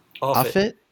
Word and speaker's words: Offutt: do I pronounce it Offutt? – Offutt.